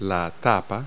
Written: lid

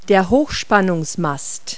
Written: pylon

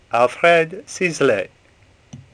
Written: Sisley

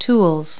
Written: tools

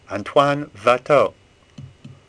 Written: Watteau

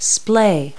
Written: splay

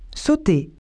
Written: jump

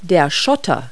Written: ballast

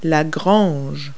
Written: barn

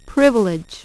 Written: privilege

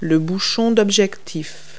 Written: lens cap

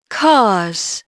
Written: cars

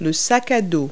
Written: backpack

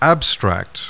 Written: abstract

